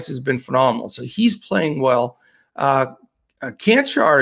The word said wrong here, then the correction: Čančar: has been uh, Čančar, I believe it's pronounced.